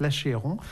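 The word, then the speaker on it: L'Achéron: L'Acheron ou l'Acheron, je ne sais pas comment on dit, mais il y a un accent aigu à la française, donc je prononce L'Acheron, de Marin Marais, extrait du deuxième livre de pièces de viol, donc composé en 1701.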